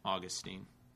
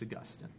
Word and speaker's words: Augustine: Some people pronounce it Augustine. They're allowed to be wrong. It's Augustine.